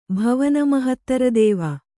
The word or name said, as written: ಭವನಮಹತ್ತರದೇವ